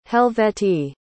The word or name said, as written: helvetii